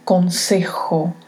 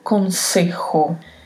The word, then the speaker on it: consejo: La letra J tem esse som que vem meio da garganta, mas que não é um som de R, como R que utilizamos no português. Falamos rota. Este mesmo som tem que ir para a pronúncia da palavra. Então pronunciamos consejo, consejo.